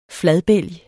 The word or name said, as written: fladbælg